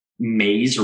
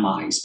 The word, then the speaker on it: Meyes: I wish I knew what the kid's name, but I think his last name is Mays, or it's not Mize, but it's spelled like M-E-Y-E-S.